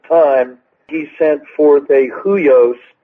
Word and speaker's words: huios: And there in the notes, I cite Galatians 4, which discusses the difference between a huios, which that H-U-I-O-S actually is pronounced in Greek with a W, and nepio, which is a little child.